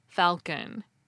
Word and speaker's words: falcon: For example, the Middle English word for falcon was F-A-U-C-O-N, but scholars stuck an L in there to make it look more like a Latin word, and now speakers pronounce the L, falcon.